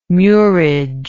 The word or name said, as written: murage